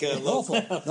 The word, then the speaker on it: Norfolk: Well, you even pronounced it like Norfolk. – Norfolk. – You did the non-rhotic R there.